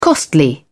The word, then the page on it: costly: British English